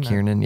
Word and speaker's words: Kiernan: Kernan or Kiernan? – Kiernan, yeah.